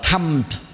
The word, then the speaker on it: Dhamma: Thì tương tự như là Dhamma thì người Ấn Độ đọc là Tham.